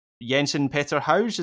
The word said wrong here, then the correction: Jens Petter Hauge: is that how you say his name? – But anyway, the guy who... Jens Petter Hauge.